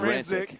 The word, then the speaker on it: Rancic: You can't call up Juliana Rancic and be like, hey, I just talked to the president. Did I say her name right? – I think it's Rancic. – But whatever. – It's Rancic, Brad.